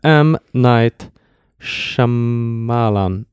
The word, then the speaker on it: Shyamalan: M. Night Shyamalan. Shyamalan. – Ja. Shyamalan. Shyamalan. Shyamalan. – Jag tror man ska inte tänka på yt så mycket.